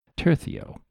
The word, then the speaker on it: tercio: The Spanish tercio, I hear you are supposed to pronounce it tercio, with a lisp.